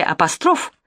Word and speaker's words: апостроф: В слове «апостроф» ударение падает на 3-й слог Майя Давыдовна, а роман этот написал не Уайльд, а Стивенсон.